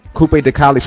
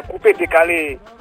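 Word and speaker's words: Coupé-décalé: Coupe de Calais. So I was pronouncing it wrong. – Yeah, Coupe de Calais.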